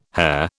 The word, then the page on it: hair: British English